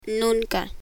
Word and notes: nunca: Spanish

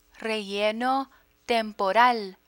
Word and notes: relleno temporal: Spanish

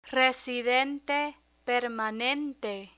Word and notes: residente permanente: spanish

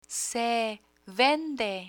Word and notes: se vende: spanish

